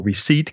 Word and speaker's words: receipt: Porque ya sabes que muchas palabras, como knife, que tiene una K que no se dice, como receipt, que tiene una P que no se dice, pues hay muchas palabras en inglés que son muy raras de pronunciar.